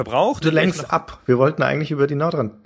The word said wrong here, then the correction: Nordland: Nord, Nordrand. Schon wieder gesagt. Das gibt's überhaupt nicht. Ich werde das heute kein einziges Mal richtig aussprechen können. die Nordland.